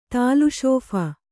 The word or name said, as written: ತಾಲುಶೋಫ